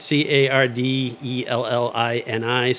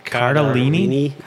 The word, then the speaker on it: Cardellini: C A R D E L L I N I. So was that Cardellini? Yeah. Cardellini, I think.